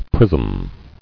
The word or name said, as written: Prism